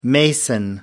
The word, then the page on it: mason: American English